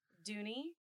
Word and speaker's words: Dooney: I am Dooney Doom. – Dooney Doom. Can we spell that? – Dooney. – Dooney. – Yes. D-O-O-N-E-Y. Oh. Doom. – Dooney. Okay. I thought it was D-U-N, like the dune, like the in the desert movie.